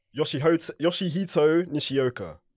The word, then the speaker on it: Yoshihito Nishioka: Yoshihito Nishioka.